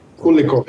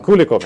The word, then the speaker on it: Kulikov: Kulikov heter han. Kulikov heter han. Ja, jag säger Kulikov av någon konstig anledning.